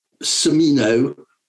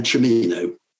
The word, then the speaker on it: Cimino: Well, the family was called Cimino, and he changed the pronunciation. It just built a tiny riff between Cimino and Cimino.